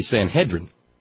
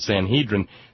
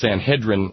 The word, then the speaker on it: Sanhedrin: But see, I'm a recovering Southern Baptist, and I grew up, and every minister I ever heard opining from the pulpit said Sanhedrin. So yeah. So, but it's Sanhedrin, like rhymes with Excedrin.